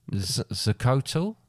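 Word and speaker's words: xocolatl: Don't pronounce the X. – Oh, right. – But pronounce more of a Z. – Xcotl. And the name for the... – Like, you know Xavier? – Yeah. Xcotl.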